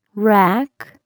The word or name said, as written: rack